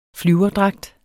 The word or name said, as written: flyverdragt